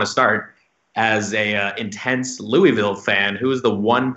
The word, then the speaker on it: Louisville: It's pronounced Louisville. – Louisville, my apologies. I'm from Boston, Louisville, so unfortunately. – Yeah. If you're really from there, if you really want people to know that you're an ally, you cut out the middle syllable and you say Louisville.